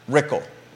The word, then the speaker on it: Rickle: Whitehall district candidates Ann Malik versus Brad Rickle. Rhymes with pickle.